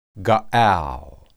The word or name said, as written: gaal